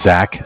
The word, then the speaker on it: Zach: My name is Zach, spelled Z-A-C-H. – Mm-hmm. – I'm calling from Bank of Cardiff. Cardiff is spelled C-A-R-D-I-F-F, like friend.